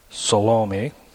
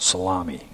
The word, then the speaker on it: Salome: If you combine the different accounts, it would include Mary Magdalene, Mary the mother of James, Mary the mother of Jesus, Joanna, Salome," I always want to pronounce that salami, I don't know why,